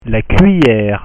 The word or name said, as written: la cuillere